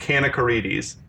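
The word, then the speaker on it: Kanakaredes: This is Melina Kanakakarides or I've heard her on TV before. – Kanakarides. – She says it's pronounced Kanakarides. It's like a can of Karides.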